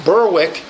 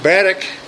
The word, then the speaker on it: Berwick: Now, if you get near Berwick, which is spelled, if you try to look it up on a map, it looks like it would be pronounced Berwick, but it's pronounced Berwick.